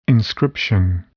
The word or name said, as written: inscription